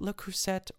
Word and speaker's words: Le Creuset: The T at the end of creuset is definitely silent. Le creuset is how it would be said in France.